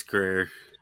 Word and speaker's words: Grier: he envisioned that role for Pam Grier or Grier or Grier. I can't remember how you pronounce it. It's Grier.